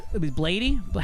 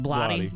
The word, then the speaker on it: Blatty: Blady? How do you pronounce it? Blatty. – I pronounced. – Blatty. – Blatty.